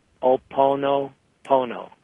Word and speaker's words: Ho'oponopono: It's Ho'oponopono. I know how to say it. That's all. So it's Ho'oponopono.